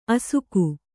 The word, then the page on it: ಅಸಗ: kannada